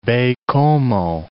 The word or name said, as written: Baie-Comeau